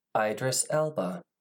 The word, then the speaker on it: Idris Elba: So, as we previously talked about, Idris Elba. I looked up how to say his name.